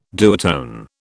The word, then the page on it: duotone: British English